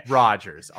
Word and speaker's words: Rogers: T-Y-B-O, Tybo. – Okay. – Rogers, R-O-G-E-R-S.